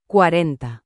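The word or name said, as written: cuarenta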